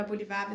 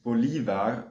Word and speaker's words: Bolívar: Was sind denn so allgemeine biografische Informationen, Hard Facts, vielleicht auch ein paar Fun Facts, die jeder über Bolívar wissen sollte? – Genau, erstmal vielleicht zu seinem Namen selbst. Man spricht ihn tatsächlich Bolívar und nicht Bolívar aus. Das ist eher die eingedeutschte Aussprache, aber in diesem Podcast werden wir ihn auch immer Bolívar nennen.